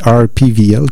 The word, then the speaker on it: RPWL: Là, je dis, faut que je prenne l'habitude, c'est RPVL, comme eux le prononcent, donc Word Through My Eyes, et on avait aussi une pièce de Trying to Kiss the Sun.